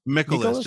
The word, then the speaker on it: Mikolas: Mikolas, just like Nicholas with an M, yeah.